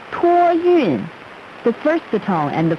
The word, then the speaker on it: 托运: 托运.